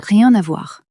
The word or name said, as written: rien a voir